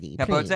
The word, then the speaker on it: Capote: Truman Capote. I'm pronouncing that correctly. – Capote.